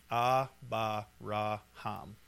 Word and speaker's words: Abraham: So when you pronounce this, it's Abba-ra-ham.